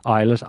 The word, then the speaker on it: Ilyes: Isles or Eyeless? – Eyeless, I-L-Y-E-S.